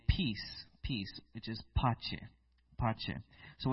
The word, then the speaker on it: pace: So pace. It's two syllables.